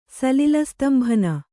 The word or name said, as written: ಸಲಿಲಸ್ತಂಭನ